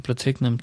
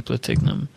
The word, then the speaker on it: Platignum: The brand owners added a silent G to Platignum to bypass the law.